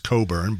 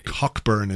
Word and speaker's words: Cockburn: I know it's pronounced Coburn, but Cockburn is much better.